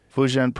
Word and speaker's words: Fujian: Fujian province met a female alien.